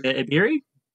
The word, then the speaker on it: Ebiri: On Twitter, I'm at Bilge Ebiri. E-I-L-G-E-E-B-I-R-I.